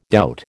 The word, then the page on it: doubt: American English